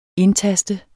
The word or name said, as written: indtaste